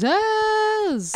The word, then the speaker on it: Zsasz: And so then we go to said Penguin, and he goes to his men and Victor Zsasz, and I'm like, oh shit, Victor's involved. – Zsasz. – And that's how you have to say his name. – Yeah, Zsasz.